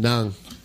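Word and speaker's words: ng: It's the Na is spelled N-G, by the way. – There it is. – For some reason. – Nung. – Nung.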